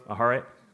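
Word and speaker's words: Aharet (אחרית): and it's the word aharet. Did I say that right, Caleb? Aharet, right? Okay, thanks. Thumbs up.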